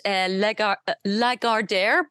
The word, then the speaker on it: Lagardère: And Lagardère, pardon me, is the pronunciation of the company.